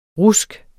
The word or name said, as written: RUSKET